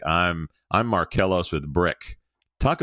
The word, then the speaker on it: Bryq: Brick sounds great, but it's spelled B-R-Y-Q.